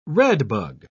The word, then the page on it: redbug: British English